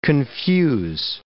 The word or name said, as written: confuse